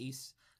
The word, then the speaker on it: Ys: It's pronounced Ys.